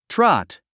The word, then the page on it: trot: trάt; トゥロォット